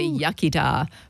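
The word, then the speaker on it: iechyd da: You say yachida, good health. – Yachida. – Yeah, the English say yachida, but it's yachida, good health.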